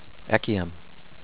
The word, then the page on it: Echium: ECH-ee-um